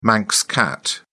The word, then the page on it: manx cat: British English